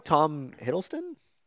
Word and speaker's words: Tom Hiddleston: I like Tom Hiddleston.